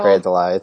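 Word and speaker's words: Grey DeLisle: Oh, Gray DeLeslie's back. – Gray DeLisle. It's spelled Leslie, yeah, but it's Gray DeLisle.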